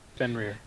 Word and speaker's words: Fenrir: Now, I have that as the story of, how do you say, Fenrir? – Fenrir.